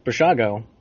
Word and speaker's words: Basiago: Yep. Looks like Basiago. – That's what I thought it was. I did see that. – I watched a video of him, and he pronounced it Bashago.